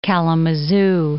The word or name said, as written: Kalamazoo